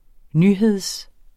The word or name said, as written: nyhed